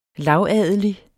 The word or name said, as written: lavadelig